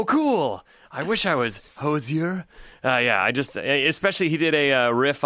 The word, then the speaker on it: Hozier: And is that how you say it? Hozier? – I think it's Hozier, right? – Yeah.